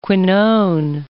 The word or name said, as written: quinone